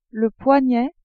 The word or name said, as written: poignet